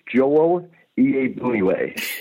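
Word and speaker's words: Joel Iyiegbuniwe: All right, it's Joel Iabuniwe. And it took me about 15 tries to get that right, and I still can't spell it right, but I can usually pronounce it.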